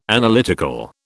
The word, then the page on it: Analytical: British English